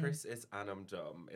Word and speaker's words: Chrisisanmdom: I'm not going to say the full thing because how do you say Chrisisanmdom? – Chrisisanmdom. It's actually Irish.